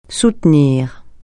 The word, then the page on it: soutenir: francais